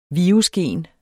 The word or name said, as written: virusgen